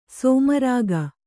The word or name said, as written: ಸೋಮರಾಗ